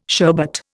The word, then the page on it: sherbet: Indian